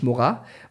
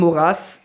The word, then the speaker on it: Maurras: Man sagt wirklich übrigens Maurras und nicht Maurras, weil Maurras aus Südfrankreich kommt, wo das ausgesprochen wird.